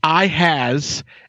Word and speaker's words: Ihas: Is that I-N-S-E-S? – It's Ihas, and it's spelled like I-H-A-S-Z. – Okay. – But but he used to on Twitter go by like Sean I has, like EYEHAS, because people could never pronounce his name.